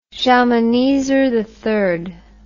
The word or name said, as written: Shalmaneser III